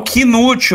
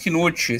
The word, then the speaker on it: Knuth: Canute? Quem é Canute? É, é um K-N-U-T-H. Às vezes tô falando errado. – Ah, o Knute. Você tá falando errado. É o Vinícius Knute. Sim, sim, já foi no Vinícius Knute. Ah, era eu que tava falando errado.